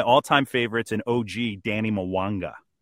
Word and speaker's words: Mwanga: It sounds it sounds like Mwanga. Yeah, and just the way it's like the two syllables, Danny Mwanga. It almost is like pop, pop, pop.